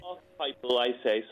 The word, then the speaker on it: archetypal: How do you pronounce that word? – Archetypal, I say. Some people archetypal. Say archetypal.